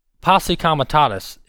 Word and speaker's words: Posse comitatus: Posse comitatus is how you pronounce that.